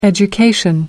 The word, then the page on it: education: American English